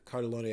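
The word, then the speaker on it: Cartellone: His name I always get it's spelled Cartel One, but Cardellone, I believe, is how it's said.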